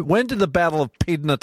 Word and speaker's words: Pydna: The Battle of Pydna. Have I said that right, Harold? – That's right.